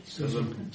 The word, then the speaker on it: schism: And what is the pronunciation? – Schism. – Schism, yes. It's schism. Yes. But we don't want to be schismatic. over this, nor do we want to make a man an offender for a word, but the pronunciation is best, schism.